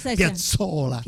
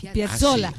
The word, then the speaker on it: Piazzolla: Y bueno, lo pronuncio como corresponde, como lo pronunciaría Zárate. – Piazzolla. Piazzolla.